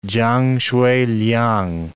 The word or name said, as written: Chang Hsueh-liang